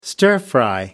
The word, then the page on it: stir fry: American English